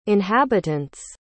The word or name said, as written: inhabitant's